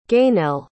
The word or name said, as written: Gainell